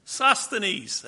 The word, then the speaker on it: Sosthenes: Sosthenes.